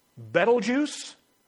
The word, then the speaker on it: Betelgeuse: There's a star that's called Betelgeuse, and it's not spelled like it sounds.